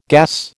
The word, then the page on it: gas: American English